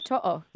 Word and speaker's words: To'o: But even your young wing partner, is it To'o? How do you say his last name? How does he say Brian? – It's To'o. – Yeah, mate. – Oh, jeez. I'll say two.